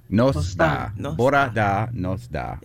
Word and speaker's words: bora da: Good morning is bora da. – Bora da.